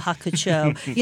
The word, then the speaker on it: Hakucho: Sorry, I need to put the long O, Hakucho.